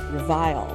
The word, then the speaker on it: Reveile: And I'm so excited to have my friend Matt Reveile here with us. It always sounds funny saying your last name because it's spelled like vile, Reveile.